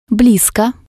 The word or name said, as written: blisko